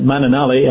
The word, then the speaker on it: Mananali: It's a difficult one to pronounce, but Mananali, M-A-N-A-N-A-L-I.